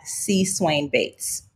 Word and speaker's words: C. Swain Bates: My handle everywhere is at C. Swain Bates. So I'll spell it. It's C-S-W-A-I-N-B-A-T-E-S.